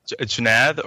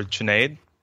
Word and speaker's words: Junaid: Junaid or Junaid. Both work. I also go by Jay. – But it's spelled J-U-N-A-I-D.